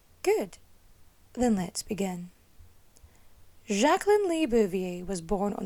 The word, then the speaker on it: Jacqueline: She's got the surname, and her name is always pronounced Jacqueline, like that's how she pronounced it.